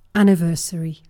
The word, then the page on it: anniversary: British English